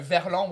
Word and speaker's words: verlan: It's the French Verlan. Ah, it's Verlan. Ah. – Le Verlan. – Le Verlan, okay. – Because you're learning French, right? – Right. I know two or three words. – So Verlan, what is Verlan? – En l'envers.